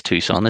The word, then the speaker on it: Tucson: How do you pronounce it in Tucson? It is Tucson, isn't it? – Tucson, yeah.